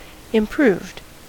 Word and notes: improved: American English

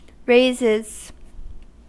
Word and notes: raises: American English

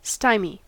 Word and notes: stymie: American English

